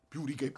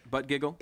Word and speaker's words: Buttigieg: This stands in stark contrast to the Fox News town halls in which me and my colleague Pete Buttigieg. – Mm-hmm. There you go. – Yeah. – Correct pronunciation. – Butt giggle.